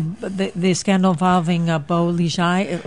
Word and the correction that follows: Bo Xilai: or I'm mispronouncing the name? – Bo Xilai.